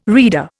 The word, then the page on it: rede: Indian